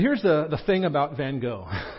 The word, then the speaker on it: van Gogh: We apparently mispronounce his name. You'll hear it the way the Brits say it here in a second. It's a Dutch name, so it sounds different than our anglicized version, but we're going to go with Van Gogh.